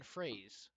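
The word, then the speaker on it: Froese: And Byron Fraze, apparently it's the proper way to pronounce his last name, even though it looks like Fries, from Montreal.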